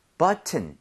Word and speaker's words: button: 앞쪽에 강세를 엄청 주셔야 돼요. 그래서 여기 강세를 많이 줘서 button 이것도 돼요. 되는데 원어민들이 앞쪽에 강세를 많이 주면서 T를 그냥 거의 없어지게 만들 때가 있어요.